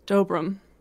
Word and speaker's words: Dobrum: Oh, D-O-B-R-U-M. – Dobrum. – Oh, yeah, Dobrum. I said it wrong.